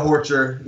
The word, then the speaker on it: Horcher: How do you say his last name? Horcher? – Darrell Horcher, yeah.